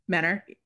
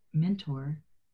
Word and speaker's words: Mentor: There's a T in there, and I over accentuate the T. Maybe it's because I have so many in my own name. But when you said that, I was like, Menor. – Yeah, they don't pronounce the T.